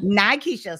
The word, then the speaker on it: Nykisha: Is it Nykisha or Nakisha? – It's Nykisha. – Nykisha. – So I said it right the first time.